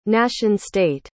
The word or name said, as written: nation state